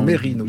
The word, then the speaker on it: Mesrine: Oui, on dit Mérine. Il y a toujours un débat sur la prononciation de son.